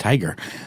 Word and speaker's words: TIGHAR: I still think that's pronounced Tiger.